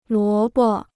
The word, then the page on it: 萝卜: luó bo